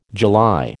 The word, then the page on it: July: American English